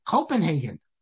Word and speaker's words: Copenhagen: The people in Denmark pronounce it Copenhagen.